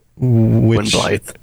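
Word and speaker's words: Gwynbleidd: Gwynblithe. Blythe. Yeah, it's the two D's. I mispronounce it. It's pronounced like a TH. So it's spelled with two D's, pronounced like TH.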